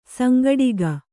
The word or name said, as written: ಸಂಘಟಕಿ